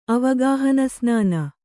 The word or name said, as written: ಅವಗಾಹನಸ್ನಾನ